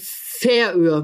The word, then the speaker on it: Färöer: Die korrekte Aussprache ist die Färöer, Betonung auf der ersten Silbe.